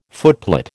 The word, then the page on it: footplate: American English